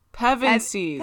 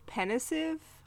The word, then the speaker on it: Pevensies: Pevensies. I'm sorry. – Okay, Lord. – My whole life, I thought it was pensive. Or pensive, which it's not.